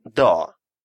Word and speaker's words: dag: En dag. День. En dag. Существительное общего рода dag стоит в неопределенной форме, на что нам указывает артикль en. Обратите внимание, что последнее g в этом случае не читается.